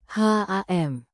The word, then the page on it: ham: Indonesian